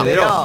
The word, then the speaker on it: Daireaux: Saben que Deró no se escribe Deró, ¿no? – No, Deraux. – Daireaux. – No le dicen así. Daireaux. – No, Deró.